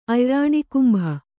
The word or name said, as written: ಅಯ್ರಾಣಿಕುಂಭ